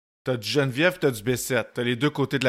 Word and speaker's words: Bessette: Geneviève Bessette, je le prononce comment? Bessette? Bessette? – Bessette. – Bessette, OK, comme la cycliste, finalement.